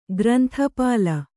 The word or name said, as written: ಗ್ರಂಥಪಾಲ